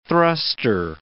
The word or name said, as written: thrustor